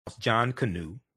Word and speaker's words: Jonkonnu: Though Jonkonnu, pronounced Jonkonnu, like a canoe that you, like a little boat, a canoe.